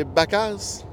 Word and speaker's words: vaca: le V en espagnol, en ça dit B. Ah. OK. Ah. – Fait que le B, fait qu'une vache, c'est Bacas. – Bacas. – Ça s'écrit V-A-C-A, mais tu vas dire Ah, Baca.